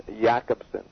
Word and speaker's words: Jakobson: He was a Czechoslovakian structuralist, Jakobson, or Jacobson, but I think they pronounce the soft J, so it's Roman Jakobson.